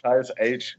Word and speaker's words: Aech: Also ja, Each. – Age. Ja, genau. Also A-E-C-H zwar geschrieben, aber ausgesprochen wie das englische H.